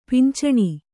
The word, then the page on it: ಪಿಂಚನಿ: pincani